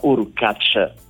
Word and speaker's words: Hurkacz: se è Urkacz, ce lo dirai tu. e questo dopo, ovviamente, dopo il ritiro di Matteo Berrettini. – La seconda pronuncia, Urkacz, però va bene qualsiasi, dai, tanto non se ne accorge nessuno.